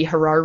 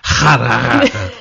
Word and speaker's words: Harar: Or as my friend Nick likes to say, Harar. How do they pronounce it locally? – They would roll the R slightly. – So it wasn't quite like that, but it was like Harar.